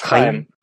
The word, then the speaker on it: Chaim: Sorry, it's Hebrew. It's Chaim. Yeah, it's like the sound.